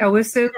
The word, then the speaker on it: Owusu: Am I saying that right, Owusu? – Correct, absolutely correct, yes.